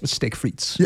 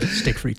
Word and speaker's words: frites: Steak frites. Yes. That's how it's pronounced. That's how the French say it. Steak frites. – Steak frites.